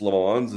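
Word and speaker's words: Lammons: but Chris Lamons, is that how you pronounce it, Matt? – That appears to be what we're going with, yes.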